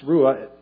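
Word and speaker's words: ruah (רוח): Interestingly enough, when it says, not by force or by strength, but by my spirit, that Hebrew word there called, that's ruah, pronounced ruah, translated as spirit, is also the word for breath.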